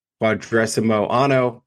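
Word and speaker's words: Quadragesimo Anno: Rerum Novarum, and then I'm going to say this wrong, but Quadresimo Anno by Pope Pius the 11th.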